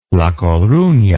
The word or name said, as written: La Coruna